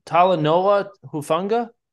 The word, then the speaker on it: Talanoa Hufanga: I think it's Talanoa Hufanga. – There you go. – Is that it? – Talanoa Hufanga. Hufanga.